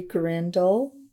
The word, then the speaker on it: Grindle: I'm Shirley Grindle, married to Carpal Zimmon Jr. And correct spelling of your name? – SHIRLEYZIMIN.